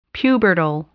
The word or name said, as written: pubertal